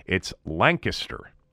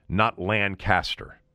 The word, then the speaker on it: Lancaster: Did you hear that pronunciation? That's the correct pronunciation. I've been told now by no less than a half dozen people, it's Lancaster, not Lancaster.